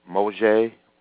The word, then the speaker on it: Moshe: And Torah means the Decalogue or the Pentateuch or the first five books of Moshe, which is Moses' Hebrew pronunciation of his name.